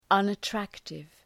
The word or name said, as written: unattractive